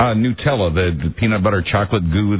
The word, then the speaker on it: Nutella: That's Nutella? – That's one. Is that how you pronounce it? I don't know. Nutella, yeah. – I guess. – Nutella, right.